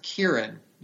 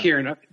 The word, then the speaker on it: Ciarán: I think it's pronounced Kieran. – Yeah. Kieran. That makes sense because I believe he's Irish.